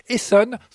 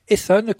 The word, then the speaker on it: Heson: On reste dans la ronde pour fêter la Bretagne avec cette fois-ci un rond de Saint-Vincent interprété par le talentueux quintet Esson, cela s'écrit H-E-S-O-N, Esson, composé de deux saxophonistes, Ewen Couriot au sax soprano et de Ilian Couriot au sax baryton.